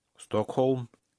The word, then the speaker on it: Stockholm: Во-первых, как читается название столицы Швеции? – Стокхолм. – Л не читается. Стокхолм.